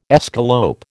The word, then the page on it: escalope: American English